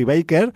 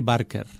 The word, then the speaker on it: Barker: Curry Barker. – Barker. – Todo el mundo se come la R y le llama Baker. – Ya, yo lo acabo de hacer. – Sí, no. – Barker.